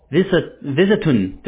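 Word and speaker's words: Bistun: Yeah, B-I-S. – T-U-N. Visitun.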